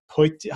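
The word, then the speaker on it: potjie: the braai boikies and the poiki. How do you say the poiki? The pot? – Poiki, yeah. – The poiki, that's it.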